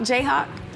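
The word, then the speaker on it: Jayhawk: Rock Chalk, J-Chalk. – You know, how you say it? Rock Chalk, J-Hawk.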